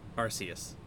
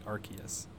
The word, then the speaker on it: Arceus: I am on Team Soft C, Arceus. – I always was too, but I've heard it the other way enough times recently that now I find myself saying Arceus.